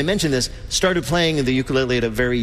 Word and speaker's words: ukulele: the ukulele. – Peter's first question to Taimane: how the hell do you pronounce ukulele? – Ukulele. – Ukulele. – You got it.